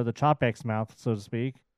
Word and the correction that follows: Chapek: Hey, I got a pronunciation of his name is Chapek. – Oh, it's not Chopek? – It's Chapek. – Oh, okay. – The A is A.